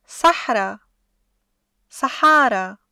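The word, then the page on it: صحرا (صحارى): Syrian Arabic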